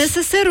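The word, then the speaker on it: несессер: НССР ударение, правильно, даже в грамоте.ру можно проверить. Это французское слово, и это специальный контейнер, вот сумочка такая, барсетка, для мелких предметов, для предметов гигиены, косметики, маникюра, ну такое вот.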